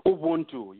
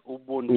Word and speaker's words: Ubuntu: And how do you pronounce it? Ubuntu or Ubuntu? – Ubuntu, yes. Ubuntu.